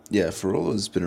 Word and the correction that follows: Frevola: around for, or how do you pronounce that? Frevola? – Frevola.